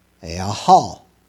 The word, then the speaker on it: yachal: Now, hope is the Greek word yahal, spelled yachal, pronounced yahal, meaning to wait, to hope, to expect.